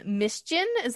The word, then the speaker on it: Mischien: And how did you come up with your artist name, Mischien? Am I saying that right? – Yeah, you're saying it right.